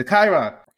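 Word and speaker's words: chyron: Is it a cryon? Is that how do you pronounce that? Or just a little headline underneath of what they're talking about? – Ah, yes, the lower third, the Chiron.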